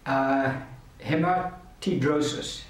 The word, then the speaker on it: hematidrosis: And the medical term is called hematidrosis, I'll probably pronounce it like that. H-E-M-A-T-I-D-R-O-S-I-S.